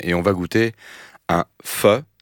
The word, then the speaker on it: pho: C'est comme ça qu'on prononce pho ? – Pho. – Pho. – Pho. – P-H-O. – Oui. – En aucun cas, c'est faux. – Non. – C'est faux de dire faux.